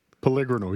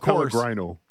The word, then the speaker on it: Pellegrino: He's talking about Frank Pellegrino. How do you pronounce it? – Pellegrino, yes. – Pellegrino. Is, of course. Or Pellegrino. – Yeah. – Both are acceptable.